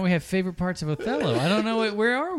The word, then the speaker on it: Othello: I can do Otello, but I can't do Richard. – Othello. It's pronounced Othello.